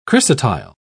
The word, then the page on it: chrysotile: British English